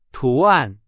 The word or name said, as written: 图案